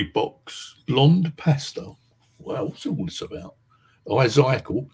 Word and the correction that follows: Ezekiel: is that how you pronounce his name? Isaacal, didn't he? In the Bible, see the flying saucer. Am I correct or am I not? – I have channeled Ezekiel.